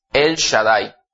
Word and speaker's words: El Shaddai: es El Shaddai, se pronuncia.